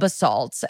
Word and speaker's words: Basalt: Like the guy that was like telling us, he goes, the place is called Basalt. And I remember now how to say it because they said Lori Kilmartin goes, it sounds like a salt.